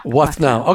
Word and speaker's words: Withnall: Debbie, are we saying your last name right? Because I keep feeling like I'm saying it wrong. Are we okay? – I say Debbie Withnall. – Withnall. Okay, we're going to make sure we get it right.